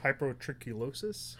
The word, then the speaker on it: hypertrichosis: Hypertrichosis? – Hypertrichosis, I think.